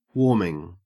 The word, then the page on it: warming: Australian English